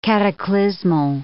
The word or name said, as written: cataclysmal